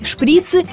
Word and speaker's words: шприц: Так вот, с точки зрения великого и могучего, правильно ставить ударение на «и», а значит будет два шприца, закончились шприцы и в аптеке нет шприцев.